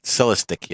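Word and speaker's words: Celestiq: How do you pronounce it? – Yeah, that's the one I was talking Celestic? – About. Celestic, yeah.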